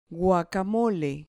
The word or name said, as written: guacamole